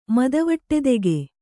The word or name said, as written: ಮದವಟ್ಟೆದೆಗೆ